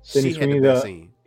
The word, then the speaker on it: Sydney Sweeney: But the Spider-Woman, the one that have the Venom-like costume, that was Sydney, Sweetie. If I'm saying the name right, Sydney Sweeney.